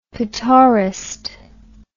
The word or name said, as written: petaurist